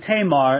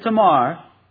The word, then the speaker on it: Tamar: It's undoubtedly incorrect pronunciation. You'd probably say her name Tamar. A's are always pronounced, never A in most other languages, but ah. And so undoubtedly it would be Tamar, but forgive me, I'm just in the habit of saying Tamar, so I'm sure I'll say that mostly tonight.